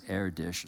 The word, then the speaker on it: Erdős: It's E-R-D-O-S. It's a Hungarian name, so you would pronounce it Erdős or something like that. And he was probably the greatest mathematician of all time, some would argue, something like that.